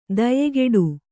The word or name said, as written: ದಾಯಗೆಡು